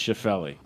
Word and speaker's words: Schauffele: But this kid, and I think, I don't know how it's pronounced, Schauffele? – I believe it's Schauffele.